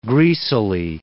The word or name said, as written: greasily